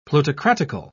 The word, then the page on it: plutocratical: British English